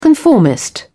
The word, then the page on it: conformist: British English